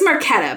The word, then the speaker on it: Marchetta: Marchetta? I said it wrong. – I think it's Marchetta, but I said it right the first time.